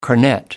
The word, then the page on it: cornet: American English